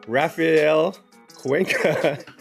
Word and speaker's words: Raphael Cuenca: So first up, let me introduce Raphael Cuenca.